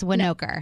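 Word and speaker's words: Winokur: I was like, I don't want to mess it up. It's Winoker.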